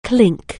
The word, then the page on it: clink: anglais